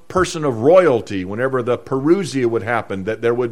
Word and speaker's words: parousia: It's the word coming in our English language, but it's the Greek word parousia, or parousia. Some pronounce it that way.